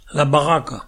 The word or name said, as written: Barraque